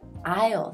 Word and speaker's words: aisle: 那还有一个要发音注意的呢，就是靠走廊的座位，这个英语叫做 aisle seat。 aisle 它的拼写是 aisle，但是读的时候呢，这个 s 不要发音，不是 aisle 是 aisle seat。